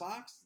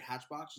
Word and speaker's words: hatbox: Hatbox? You have to like think about to not pronounce the CH. Yeah, like exactly. The hatbox ghost. But like, I agree, like hatchbox just flows out.